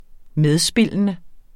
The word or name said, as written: medspillende